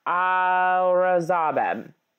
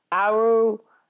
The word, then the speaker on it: Aurangzeb: Begum Nasir Akar Aurangzeb. Sorry, that is mispronounced. Aurangzeb. Pardon me.